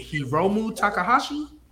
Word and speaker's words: Hiromu Takahashi: To this other mid-carder, is it Hiromu Takahashi? – Yes, ooh.